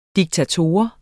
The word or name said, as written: diktator